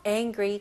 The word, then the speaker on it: angry: 네, 발음은요. ang 바로 ng. ng 그 발음으로 가셔야 돼요. n 발음이 아니고요. angry. angry. angry. 강세가 어디 들어가죠? 네. angry. 네. 첫 번째 음절에 들어가죠.